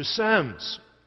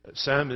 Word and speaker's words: Psalm: Psalm is my pronunciation of the word P-S-A-L-M, Psalm, which I'm working on the American pronunciation. Actually, I'm still trying to work on the English pronunciation, which is slightly different from the Scottish, but I like to think the Scottish one is the original.